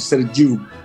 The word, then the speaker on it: Sergiu: Sergio, pero pues en rumano se pronuncia Sergiu.